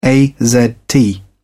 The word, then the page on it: azt: British English